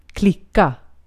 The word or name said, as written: klicka